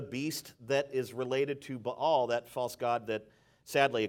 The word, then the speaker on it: Baal: Some call it Baal, but it's actually pronounced Baal, B-A-A-L. So Baal I would prefer. If you say Baal, I'm going to bail on you.